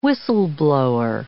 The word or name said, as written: whistle-blower